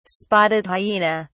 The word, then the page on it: spotted hyena: British English